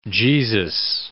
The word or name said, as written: Jesus